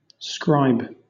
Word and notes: scribe: British English

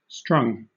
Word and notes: strung: British English

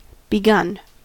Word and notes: begun: American English

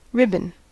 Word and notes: ribbon: American English

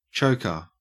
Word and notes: choker: Australian English

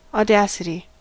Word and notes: audacity: American English

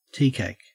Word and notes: teacake: Australian English

